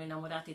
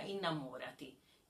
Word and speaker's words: innamorati: Innamorati della Vita, ma la stessa parola spostando solamente l'accento diventa innamorati. Quindi può essere aggettivo, innamorati della vita, ma anche invito a rinnamorarsi della vita, innamorati della vita.